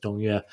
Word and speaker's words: Dong Yue: his name goes by a number of pronunciations, but this is the pronunciation I'll stick with, Dong Yue.